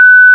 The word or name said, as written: amey